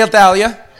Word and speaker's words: Athaliah: 2 Kings 11 says when Athaliah, say Athaliah. Now we all agree on how to pronounce that.